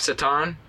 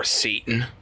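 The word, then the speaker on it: Šatan: Miroslav Satan. – Oh, wow. – Or Satan, if you don't know how to pronounce hockey names right.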